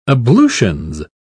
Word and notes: ablutions: British English

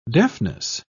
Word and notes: deafness: British English

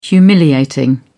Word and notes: humiliating: British English

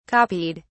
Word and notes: copied: British English

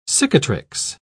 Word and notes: cicatrix: British English

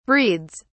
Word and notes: breeds: British English

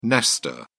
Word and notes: nester: British English

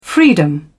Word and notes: freedom: British English

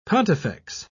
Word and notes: pontifex: British English

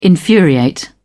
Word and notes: infuriate: British English

